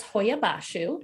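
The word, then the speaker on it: Hoia Baciu: So that's Hoia Bashu.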